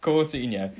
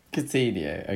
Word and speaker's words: Coutinho: The Coutinho zone, not. Coutinho. It's fine, Tim. You just said it wrong, exactly the same way. – So it's Coutinho. – Yeah. – Coutinho. Okay, got it.